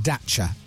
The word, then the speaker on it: Dachshund: It's pronounced Dacha. – Dacha. Yeah. Do you promise? – Yeah. Dacha. – Yeah. – You know, like Hyundai and Hyundai and stuff. It's many different ways of saying it, but you're saying Dacha is how you say it.